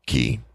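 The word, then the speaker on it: cay: It looks like the word K-A-Y-E, but it's pronounced key.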